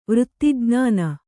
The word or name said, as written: ವೃತ್ತಿಜ್ಞಾನ